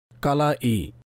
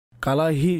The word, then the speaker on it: কালাই: শব্দটা হচ্ছে কালাই, যা হামজার মাধ্যমে উচ্চারণ করা হয়। কালাহি শব্দটা সঠিক না, সুতরাং কালাইতেও হুল ফুটায় এবং শরীরের অন্যান্য অঙ্গতেও হুল ফুটাতে পারে।